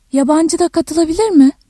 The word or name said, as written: yabancı